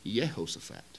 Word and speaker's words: Jehoshaphat: Like, see, we see a J and we pronounce it with a J sound. Well, that didn't exist. It was used with a Y sound. So the name Jehoshaphat would have been Yehoshaphat.